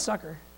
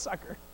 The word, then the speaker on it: succor: No, it's pronounced succor. Okay. I'm telling you, it's pronounced succor. I think you can pronounce it either way.